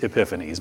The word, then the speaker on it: Epiphanes: It's E-P-I, not E-P-H. It's not Epiphanes, it's Epiphanes, my apologies.